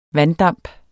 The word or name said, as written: vanddamp